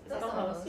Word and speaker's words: Dos Ojos: How do you say eyes? – Dos Ojos, you're right.